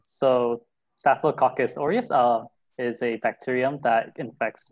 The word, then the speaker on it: Staphylococcus aureus: it's called Staphylococcus aureus. Is that the correct pronunciation? – You're really close. It's Staphylococcus aureus, but...